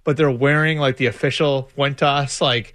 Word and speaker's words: Juventus: At his soccer practices, there are a couple guys, is it Fuentes? How do you pronounce it? Like the Italian, that big club in Italy. – I don't know. – Juventus or something like that. – Juventus. Yes.